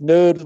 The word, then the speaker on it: Knode: but the Knode project, Knode with a silent K at the beginning.